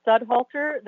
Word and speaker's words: Studhalter: My name is Kim Studhalter. That's spelled S as in Sam, U-D-H-A-L-T-E-R.